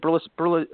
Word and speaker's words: Berlusconi: Berlusconi.